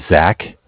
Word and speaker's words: Zach: My name is Zach, spelled Z-A-C-H. I'm calling with the Bank of Cardiff. Cardiff is spelled C-A-R-D-I-F-F.